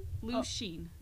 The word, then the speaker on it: Lucien: Lucien. Like ocean, but Lucien.